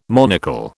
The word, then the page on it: monocle: British English